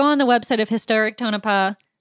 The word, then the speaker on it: Tonopah: I think it's Tonopah. The only reason why I know how to say it, even though I keep messing up, is because of the Clownvis video, I will admit, because he says Tonopah multiple times.